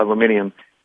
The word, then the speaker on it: aluminium: You know, they grew up together in the same area, and it was their aunt that owned and they put another syllable in aluminum called aluminium, owned an aluminium processing plant like a Reynolds aluminum, only a lot smaller.